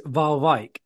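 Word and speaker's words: Waalwijk: I think it is Valwijk. That is how it's actually pronounced, because obviously in Dutch the W's are usually a V, and then Valwijk. – It's like Van Dyke, isn't it? It's that J is a is it's a soft J.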